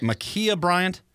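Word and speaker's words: Ma'Khia Bryant: Yeah, this poor girl that was killed, and I'm going to – is it Makia Bryant? Is that how you say it? I believe that's how you pronounce it, yes.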